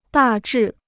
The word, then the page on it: 大致: dà zhì